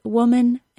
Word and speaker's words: woman: If you understand schwa, though, you should be expecting that A to get reduced. Maybe if woman weren't such an odd word phonetically, the W-I-M-M-E-N spelling of women could have become the norm.